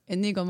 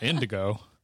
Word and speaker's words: Inigo: And so you were right about, I can't even say his name now, but Inigo Montoya. – Yeah, it's not Indigo.